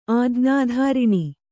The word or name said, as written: ಆಜ್ಞಾಧಾರಿಣಿ